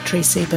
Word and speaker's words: Treacy: Is it Treacy? – I've always pronounced it Treacy, but... – Treacy. – But don't quote me.